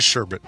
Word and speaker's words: sherbet: Orange sherbet. Okay. Yeah. All right. And it's pronounced sherbet, isn't it? You don't pronounce that. So even though that second R is in there, you don't pronounce it.